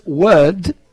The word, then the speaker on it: word: Es, una es word, word, y la otra tiene una L, world. Es world.